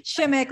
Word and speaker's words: Shemek: Shemek, like lick. Shemek. Yeah. Shemek.